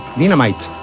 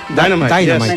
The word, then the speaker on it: dynamite: Dynamite. – ¿Cómo se dice? Dynamite. Dynamite.